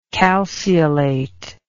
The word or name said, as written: calceolate